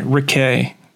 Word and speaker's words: Riquet: You have sponsorships, Ricky. HRC clamps. – He does know how to pronounce his name. – Sorry, Riquet. I'm sorry. He's French.